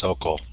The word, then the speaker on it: Socol: Uh, tell me how you say your name. – Socol. Sounds what you got it just right.